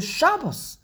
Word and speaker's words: Shabbos: In Yiddish, it's the way you say the word is Shabbos, because every single word in Yiddish, the emphasis is on the first syllable.